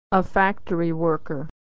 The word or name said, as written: worker